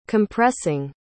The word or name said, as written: compressing